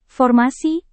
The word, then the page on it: formasi: indonesian